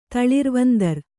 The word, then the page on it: ತಳಿರ್ವಂದರ: kannada